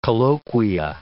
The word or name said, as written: colloquia